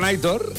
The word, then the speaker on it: Aitor: que dice, por favor, que Aitor se pronuncia como se lee. – Sí, no. – Aitor. – Dice, Aitor. La acentuación es en la O.